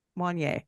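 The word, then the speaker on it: Wanyá: Then they got Wanya to come. Is it, how do you pronounce his name? Is it Wanya or Wanye? – Wanye.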